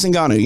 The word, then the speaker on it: Ngannou: I'm going to say it wrong, but Ngannou? – Yeah, Francis Ngannou.